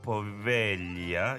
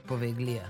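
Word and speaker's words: Poveglia: U Italiji postoji ostrvo uh, e, Povelja, jo, ja ne znam da izgovorim. – Poveglija.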